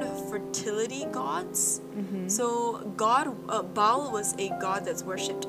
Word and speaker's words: Baal: So it's either pronounced Baal or Baal, but I'm going to say Baal.